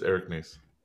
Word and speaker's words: Nies: Have I been saying his name wrong the entire time? It's Nies, not Nies? – It's Eric Nies.